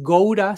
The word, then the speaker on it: Gaura: Es Goura, se escribe Gaura, pero se pronuncia gore lila.